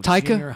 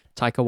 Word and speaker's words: Taika: Taika. – High. Taika. – Takai Waititi. – Taika. Waititi. Taika. – Taika Waititi. – I mean, I'm probably saying it wrong too, but it's Taika.